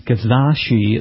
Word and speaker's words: Kizashi: has a great vehicle with a very difficult name to pronounce. It's called the Kizashi. It sounds like some sort of sushi.